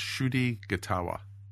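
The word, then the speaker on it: Ncuti Gatwa: The way to pronounce his name is Shudi Gatawa. – Shudi Gatawa. Well, yes. Welcome to the. – The N is silent.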